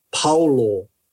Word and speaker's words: Paulo: Samoan players and the vowel sounds on the, on the surname is completely different to what the commentators are saying. So instead, it's actually, we'll go with, say, Junior Paulo. So like Paul, like boom, boom, pow.